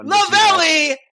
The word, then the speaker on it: Lavallee: It's like belly, Labelle, but rhymes with belly. Yeah. – Rhymes with belly. So it's Dana freaking Lavallee.